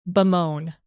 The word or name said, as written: bemoan